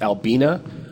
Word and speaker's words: Albina: And the fact that I pronounce it Albina instantly makes people recognize that I know Portland history.